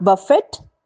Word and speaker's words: buffet: Third one, B-U-F-F-E-T. It is not buffet, but it is buffet. Buffet. Fourth word, D-I-A-R-Y.